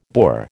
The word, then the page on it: Boer: American English